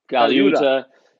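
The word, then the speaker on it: Colaiuta: Because he's had, Yeah, his drummers have been Dean Castronovo, Vinny Caliuta. Yep. Am I saying that right? – Yeah, Caliuta. – I mean, he's just had this who's who of drummers, and then I'm like, are you sure you got the right guy?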